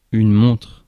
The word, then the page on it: montre: /mɔ̃tʁ/